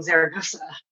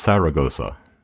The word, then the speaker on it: Zaragoza: And that's how it became Zaragoza. – I also read that it was sometimes pronounced Saragossa. – Yes, that's the English way to say it, with the S's instead of the Z's.